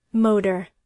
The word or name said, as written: motor